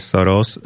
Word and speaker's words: Soros: im sa nepáči, že v ktorom jazyku sa Soros vyslovuje Soros, lebo oni zrejme chcú, no, aby...